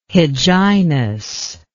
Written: hyginus

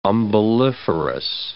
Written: umbelliferous

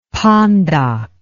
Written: pondok

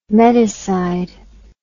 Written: medicide

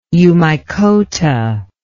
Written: eumycota

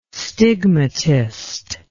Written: stigmatist

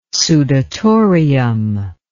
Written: sudatorium

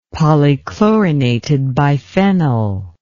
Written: polychlorinated biphenyl

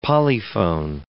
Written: polyphone

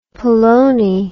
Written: polone

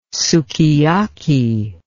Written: sukiyaki

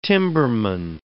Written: timberman

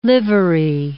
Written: livery

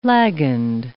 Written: lagend

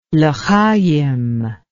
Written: lechayim

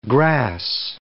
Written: grass

